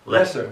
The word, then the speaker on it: Leicester: How do you say it correctly? – Leicester. It depends on whereabouts in UK you are.